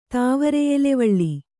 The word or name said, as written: ತಾವರೆಯೆಲೆವಳ್ಳಿ